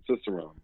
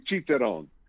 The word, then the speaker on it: Cicerone: Yeah, well, it's Italian word, but we use the English pronunciation, Cicerone. So otherwise, it would be Cicerone. But we say the American way, the Cicerone program.